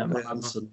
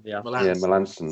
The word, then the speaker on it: Melancon: Is it Melancon? Melanson? – Yeah, Melanson. – Melanson, that's it.